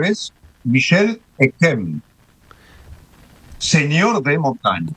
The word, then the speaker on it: Montaigne: Porque en el francés contemporáneo se pronuncia Montaigne. – Montaigne, sí. – Pero en el francés del siglo XVI, la I, que genera ese sonido en francés de E, una E abierta, cumplía otra función fonética y se pronunciaba montaña. – Uh-huh. – Lo cual quiere decir montaña, sencillamente. Es decir, que si lo fuéramos a pronunciar la vieja usanza detestable, por otra parte, de castellanizar los nombres, podríamos decir Miguel de Montaña.